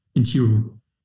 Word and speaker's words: Inteero: Man sagt ja Intiro oder, in, oder Intero. Wie spricht man es denn richtig aus? – Intiro. – Intiro, okay, sehr gut.